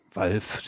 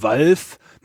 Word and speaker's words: Valve: Valve, sehr deutsch ausgesprochen, von Valve, man spricht es wie Ralf. Also von Valve.